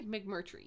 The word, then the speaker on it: McMurtry: McMurtry.